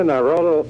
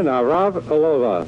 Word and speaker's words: Navratilova: Here's today's sports hero, Martina Navratilova.